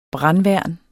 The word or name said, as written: brandværn